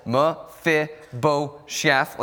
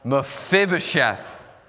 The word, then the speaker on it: Mephibosheth: The stress is on the second syllable, Mephibosheth. Let's try and say this together. Ready? 3, 2, 1, Mephibosheth.